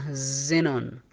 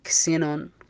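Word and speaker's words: xenon: تنطق هنا زينون، ما تنطق كسينون، تنطق زينون.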